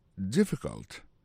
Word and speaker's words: difficult: Is it difficult? Là, ça veut dire difficile. Lié plus comme ça, D-I-F-F-I-C-U-L-T. Difficult. On a remarqué que l'accent tombé sur le premier syllabe.